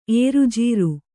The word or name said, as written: ಏರುಜೀರು